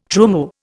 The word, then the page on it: trumeau: Indian